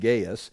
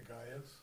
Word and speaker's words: Gaius: Now, Gaius can be, it's pronounced Gaius. In English, it could be pronounced Gaius.